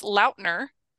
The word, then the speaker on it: Lautner: Or Lautner. Apparently, whatever. We've been saying his name wrong all this time. – Oh, really? – Yes, he came out and he did an interview and he's like, it's Lautner.